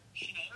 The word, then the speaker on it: Sinéad: Sinéad O'Connor.